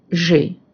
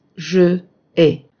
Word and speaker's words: j'ai: As you see, we say j'ai and not je ai. We do this ellipse of the final e in je to avoid contact between two vowels.